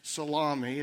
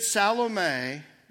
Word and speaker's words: Salome: By the way, the third woman mentioned there, her name is not pronounced Salami, as some of you maybe have pronounced. She was not the inventor of a strange new lunch meat, but instead Salome was most likely the mother of two of Jesus' first 12 followers.